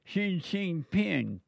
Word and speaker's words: Xi Jinping: Xi Jinping.